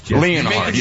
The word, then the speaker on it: Leonhard: are a lot of names that are not pronounced the way they are spelled, including Jim Leonhard. – Leonhard, yes. – Way more difficult than it needs to be. You know it's Jim Leonhard.